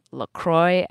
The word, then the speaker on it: LaCroix: Choi is a cultural correspondent, and she came out in the New York Times Magazine as a full-blown LaCroix addict. – Yes, that's really how it's pronounced.